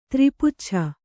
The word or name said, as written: ತ್ರಿಪುಚ್ಛ